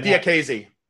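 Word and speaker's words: Diakiese: By the way, the guy that Duffy lost to last time out, Mark Diakis, he's also, I don't know if I pronounced that correctly. – Yeah, Diakese. – Oh, Diakese. Oh, I was way off.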